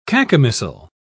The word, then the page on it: cacomistle: British English